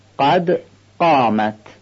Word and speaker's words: قد قامت: باید هممون قاف رو به حالت صحیح تلفظ کنیم. برای این که قاف شبیه غین نشه باید قاف رو محکم بگیم، قد قامت، سفت و محکم تلفظ کنیم.